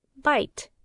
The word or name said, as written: bite